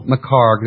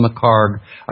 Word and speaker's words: McHarg: The author's name is Ian L. McCarg. That's spelled M-C-H-A-R-G. Ian L. McCarg.